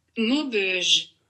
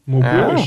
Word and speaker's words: Maubeuge: Ich glaube, es wird Maubeuge ausgesprochen. Ich hol mir mal Hilfe kurz. – Maubeuge. – Ja, Maubeuge. Ah.